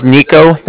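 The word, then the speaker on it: Niko: My name is Nico. That's N-I-K-O.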